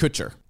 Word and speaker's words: Kutcher: Did I not say his last name right? How do you say it? – Kutcher. – Kutcher? Kutcher. What did I say? – Kushner? That Kushner, that's the Kutcher.